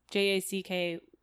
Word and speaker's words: Jacky: My name is, it's Jacky. That's how you spell it. – Fuck. – I'm so sorry. It's not IE the whole time.